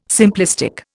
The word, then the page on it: simplistic: Indian